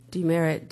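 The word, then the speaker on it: DeMeritt: That's spelled M-E-L-O-D-Y, Demerit, D-E, capital M-E-R-I-T-T.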